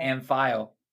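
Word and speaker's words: Amphail: Amphile.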